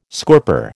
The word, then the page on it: scorper: American English